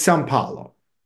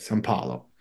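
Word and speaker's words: São Paulo: It's Sao Paulo. That's how you say it, Sao Paulo.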